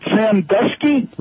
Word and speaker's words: Sandusky: Sandusky? – Right.